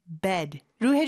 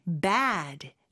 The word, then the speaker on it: bed: 에 발음보다는 좀 더 약간 눌러주는 느낌의 bed로 해주셔야 돼요. 이게 중요한 게 bad, 나쁜의 bad하고 발음이 좀 헷갈릴 수가 있어요. 그래서 나쁜은 bad, 입을 좀 더 크게 벌려주고 침대는 bed, 에 발음으로 좀 더 눌러주는 느낌으로 해주시면 됩니다.